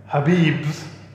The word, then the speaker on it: Khabib: Because you are saying Khabib's name wrong. – It's Khabib. There's a K. – No, there's the K is silent. – The K is silent, okay.